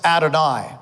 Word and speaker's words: Adonai: If you're reading the version that's done accurately, it says capital L-O-R-D. And in actual fact, if you read it in the Hebrew, it's pronounced Adonai.